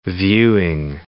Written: viewing

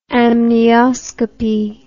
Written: amnioscopy

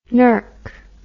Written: nerk

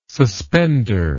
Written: suspender

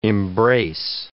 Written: embrace

